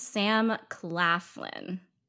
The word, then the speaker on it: Claflin: Yeah, his name's Sam Claflin. – I know that's how you say it, his last name.